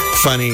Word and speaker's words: Funnie: And that's, I think that's F-N-N-I? – Correct. It's pronounced Funny. It's spelled F-U-N-N-I-E. – F-U-N-N.